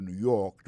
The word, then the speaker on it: New York: Si nous voulons pour un exemple côté il tombe dans dernière, I am going to New York tomorrow. New York là c'est accent tombé dans dernier mois.